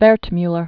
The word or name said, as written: Wertmuller